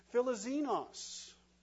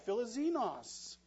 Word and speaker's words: philoxenos: On your note page, and we'll put it up on the screen as well, the word hospitality comes from a Greek word pronounced philoxenos. You can give that a try, philoxenos.